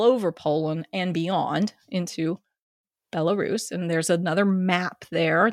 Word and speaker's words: Belarus: Belarus? I don't know how to pronounce it. – It's Belarus.